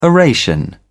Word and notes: oration: British English

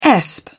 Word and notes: esp: British English

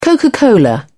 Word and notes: coca cola: British English

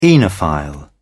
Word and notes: oenophile: British English

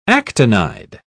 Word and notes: actinide: British English

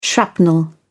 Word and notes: shrapnel: British English

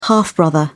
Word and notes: half-brother: British English

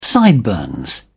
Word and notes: sideburns: British English

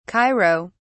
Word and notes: cairo: British English

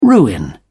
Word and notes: ruin: British English

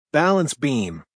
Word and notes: balance beam: American English

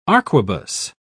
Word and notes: arquebus: British English

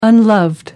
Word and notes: unloved: British English

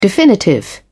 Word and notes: definitive: British English

